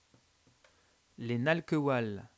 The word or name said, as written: nalcoehual